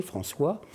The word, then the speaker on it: François: Mon nom d'ailleurs français est un peu compliqué à prononcer, François en birman.